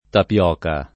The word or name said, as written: tapioca